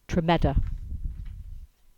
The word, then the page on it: Tremedda: English